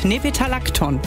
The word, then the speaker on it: Nepetalacton: Nepetalacton.